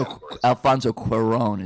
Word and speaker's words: Cuarón: Well, his name was Alfonso Cuarón is actually how you pronounce it. – Cuarón, yes.